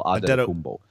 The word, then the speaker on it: Antetokounmpo: Antetokounmpo. Yeah, you emphasize the... Yeah, it's more the you said it right, but it's more the emphasis of the syllable. Antetokounmpo.